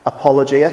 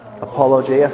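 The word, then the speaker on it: apologia (ἀπολογία): So clear and simply, the word apologetics comes from the word apologia, pronounced apologia. Can we all say that? Apologia.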